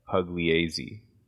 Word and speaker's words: Pugliese: I also realized I was saying his name wrong. It's Augustus Pugliese, not Pugliotti. I thought it was Pugliotti, but it's Pugliese.